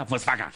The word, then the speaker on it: Volkswagen: Volkswagen, que se pronuncia Volkswagen.